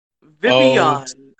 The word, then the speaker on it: Vivillon: Vivillon, I believe, is the actual pronunciation. I've always said Vivillon, but I think it's Vivillon.